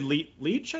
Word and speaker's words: Leitch: Good job, David Leitch, I think his last name is. – Yeah, that's how I pronounce it, yeah.